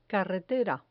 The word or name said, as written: carretera